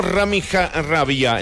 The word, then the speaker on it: Rami Rabia: Rami Rabia, el número 5, sí. Así se pronuncia, compañeros.